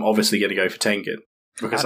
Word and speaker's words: Tengen: Guts from Berserk against Tenzin from Demon Slayer. I honestly forgot his name for a moment. – Tengen.